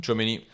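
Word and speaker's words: Tchouaméni: Die haben das Geld für Chuami, äh, Chuani. Chouamini. Äh, sorry für meine Aussprache, aber Aurelien Chouamini.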